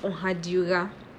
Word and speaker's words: Ohadura: So my surname is pronounced Ohadura. Now people say, some people pronounce it Oha or Ohadura, or like, it's just, let's not even go into the different pronunciations of names,